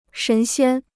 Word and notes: 神仙: shén xiān

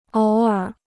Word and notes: 偶尔: ǒu ěr

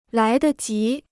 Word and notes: 来得及: lái de jí

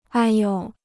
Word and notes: 哎哟: āi yō

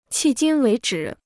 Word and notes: 迄今为止: qì jīn wéi zhǐ

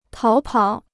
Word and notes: 逃跑: táo pǎo

